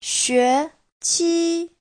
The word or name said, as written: xue2qi1